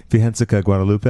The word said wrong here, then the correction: Virgen de Guadalupe: Did uh-huh. I have uh-huh. that right? Close. Virgen de Guadalupe.